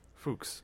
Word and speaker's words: Fuchs: The writer attached to this movie, his name is Jason, and his last name is spelled F-U-C-H-S. – Fuchs. – Yeah. – No, it's Fuchs.